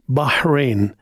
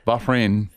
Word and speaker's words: Bahrain: Bahrain. – Bahrain.